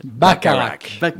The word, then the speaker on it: Bacharach: Bacharach.